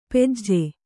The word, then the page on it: ಪೆಜ್ಜೆ: pejje